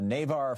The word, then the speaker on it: Navarre: John in Navarre, Florida. Am I saying that right? N-A-V-A-R? What's up, John? – Yeah, that's correct, Tom.